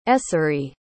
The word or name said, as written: essery